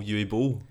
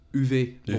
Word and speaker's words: Uwe Boll: Were they all Uwe Boll? – That is not how you pronounce it. It's Uwe Boll.